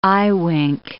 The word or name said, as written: eyewink